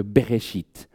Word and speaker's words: Bereshit: Mais pour un hébraïsant, pour quelqu'un qui parle l'hébreu, il y a comme un jeu de mots dans ce Bereshit. Parce qu'on entend, la deuxième syllabe, c'est Esh. Et Esh, ça veut dire le feu en hébreu.